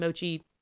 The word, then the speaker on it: mochi: But yeah, it's an ice cream mochi. I think that's how you say it. Mochi, right? – Yes.